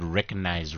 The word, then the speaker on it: recognize: I used to say the word recognize wrong. – Recognize. – I used to pronounce the G strong. Like, yo, recognize. – But that's how you're supposed to say it.